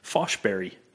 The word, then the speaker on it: Forsberg: Whose last name is pronounced Foshberry.